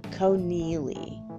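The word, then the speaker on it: Conneely: His last name is spelled C-O-N-N-E-E-L-Y, I believe. Connelly. Like Connelly, but there's two E's, Connelly.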